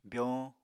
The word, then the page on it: byoñ: apatani